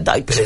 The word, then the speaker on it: Dutch: Deutschfobia. – Dutch. Hay que decir que los holandeses... – Ahora, sí, con Dutch, pero se pronuncia Dutch.